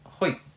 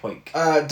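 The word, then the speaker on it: Huyck: Directed by Willard Hook, but with a Y. So I don't know if I'm pronouncing that right. – Willard Hoik. – Hoik. – Yeah, let's say Hoik.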